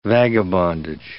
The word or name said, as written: vagabondage